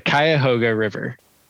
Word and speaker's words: Cuyahoga: And okay, you're going to have to help me make sure I say this right. But this Cuyahoga? – Yes, the Cuyahoga River.